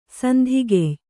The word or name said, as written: ಸಂಧಿಗೆಯ್